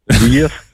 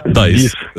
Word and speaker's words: dies: Дієс. – Так, тільки англійською дієс, як читається. От пишеться D-I-E-S. Дайс.